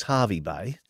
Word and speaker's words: Hervey Bay: Is it Hervey Bay or Harvey Bay? – Well, it's Harvey Bay.